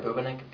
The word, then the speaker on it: Bubenik: Mei Hui, it's spelled M-E-I-H-U-E-I. – Thank you. – Tyler Bubenik, B-U-B-E-N-I-K.